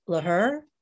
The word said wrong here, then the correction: Lecker: the caretaker of the Jewish cemetery. – The caretaker? Oh, Moses Lecker. – Lecker.